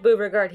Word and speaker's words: byooregard: Maybe it's Boyoregard. I need to decide how I'm pronouncing this because we're probably going to say their name a lot. – Well, I like Beauregard hearing it better, so let's just stick with that.